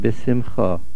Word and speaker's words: b'simcha: Ivdu es Hashem b'simcha. B'simcha ends with a kometz hay. The word is b'simcha.